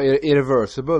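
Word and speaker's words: Irréversible: Och då har jag i filmen Irrevestible heter den. Jag är dålig på att uttala det franska. Vet du vilken film jag menar? Med Vincent Cassel och Monica Bellucci. – Ja, Irreversible menar du.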